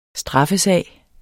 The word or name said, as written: straffesag